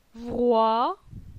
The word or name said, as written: Vroi